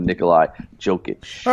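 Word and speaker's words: Nikola Jokić: But it's always one of those scary things when it comes to Nikola Jokic. – All right, fair enough, and I like your pronunciation right there.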